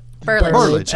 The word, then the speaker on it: Burlage: And we return with a few more moments here as we talk about love life with Jennifer Geralds and Shaylene Burlage. Did I say that right? – Burlage. – I knew close. – That didn't sound right. Burlage.